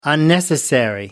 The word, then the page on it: unnecessary: American English